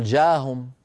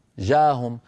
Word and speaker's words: جاءهم: يعني مثلا يا بني لا تقل جاهم جاءهم، بين الألف والجيم هناك همزة، جاءهم أهم، هذه الهمزة لابد من نطقها.